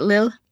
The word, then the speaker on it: Lille: Am I saying that right, Lille? – Yes. – Lille.